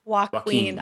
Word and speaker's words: Joaquin: So going down the line, Andrew, I have you as Joaquin Torres, or how do you say that? – Joaquin. – I love that. – Joaquin Torres. – Like I'm Joaquin. a keen sense of whatever.